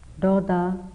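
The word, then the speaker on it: dosa: Raga, R-A-G-A, raga. Dodha, D-O-S-A. S is pronounced as TH, no? Ill will. Moha, M-O-H-A, moha.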